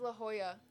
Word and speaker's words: La Jolla: I was gonna say it's La Jolla. It's La Jolla.